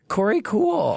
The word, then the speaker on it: Korey Kuhl: YouTube's Corey Cool. – How do they spell it? K-O-R-E-Y-K-U-H-L.